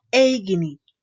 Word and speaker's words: aigini: Por ejemplo, se escribe aigini y se pronuncia eigini.